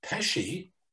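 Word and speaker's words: Pesce: Plus, he mentors students from William Patterson University's, I believe that would be pronounced Pesci. – Yes, it is. – Institute. Yep. Like Joe Pesci.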